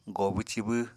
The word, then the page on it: goobw-cibw: Apatani